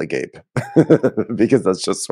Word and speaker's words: agape: The venue is called, like, I don't think it's pronounced this way, but it is definitely spelled Agape, A-G-A-P-E. I think it's probably pronounced like Agape. – It's Agape.